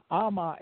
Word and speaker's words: ama: Ama is water in Cherokee. It's spelled A-M-A.